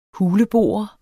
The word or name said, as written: huleboer